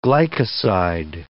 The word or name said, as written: glycoside